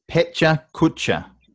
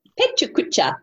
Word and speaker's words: Pecha Kucha: And just to, that is pronounced Pecha Kucha. – Pecha Kucha. – Pecha Kucha. I thought you said pet culture.